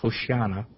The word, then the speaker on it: Hoshana: So in the phrase Hosanna, which is actually pronounced Hoshana,